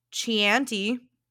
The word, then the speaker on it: Chianti: Another one with a weird spelling that I had to figure out at the end of the word how it was actually pronounced is Chianti. Chianti. If I didn't know any better, I would say Chianti.